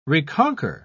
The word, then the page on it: reconquer: British English